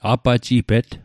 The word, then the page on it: apajipet: Mi'gmaq